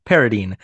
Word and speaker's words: Paradine: Paradine. I've made note of the fact that it's pronounced Paradine and then said it wrong in the intro.